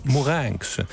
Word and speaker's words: Mourenx: Mourenx.